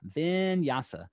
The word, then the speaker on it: vinyasa: And today we're going to talk about the fifth pillar of Baptiste yoga, which is vinyasa. Vinyasa. Sounds like a kind of a wine, vinyasa, but really vinyasa is flow.